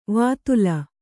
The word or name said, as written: ವೇತಾಲ